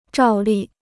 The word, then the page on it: 照例: zhào lì